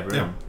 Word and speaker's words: Brenham: Yeah, Brenham.